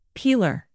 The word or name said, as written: peeler